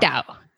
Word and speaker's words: Qingdao: Qingdao.